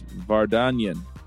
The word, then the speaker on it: Vardanyan: I love getting people from other countries because I'm looking at the name and going, let me try it. It's Vardanyan. – Yeah, you got it mostly right. yeah, Armenian words usually are pronounced as they are written.